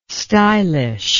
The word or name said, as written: stylish